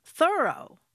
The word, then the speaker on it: Thoreau: All this time, I thought his name was pronounced Thoreau, but no. – How is it pronounced then? – Thoreau. – Thoreau. I'm thoroughly did it. – Yeah, or I'm thoroughly surprised that it's pronounced that way.